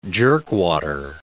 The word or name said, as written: jerkwater